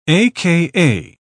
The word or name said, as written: aka